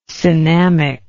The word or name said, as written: cinnamic